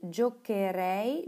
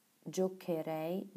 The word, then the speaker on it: giocherei: Bei den Verben auf care und gare wird im Konditional ein h eingefügt. Zum Beispiel giocare, spielen, Würzu giocherei, giocheresti. Da kommt nach dem C ein H, damit es giocherei, giocheresti ausgesprochen werden kann.